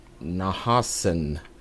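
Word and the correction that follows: Nohansen: Hyrule. Nahansen. I said that wrong.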